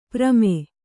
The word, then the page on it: ಪ್ರಮೆ: prame